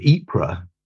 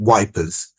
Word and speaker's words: Ypres: They discovered in the bombed out ruins of Ypres, which the Tommies couldn't pronounce, so they called it Wipers.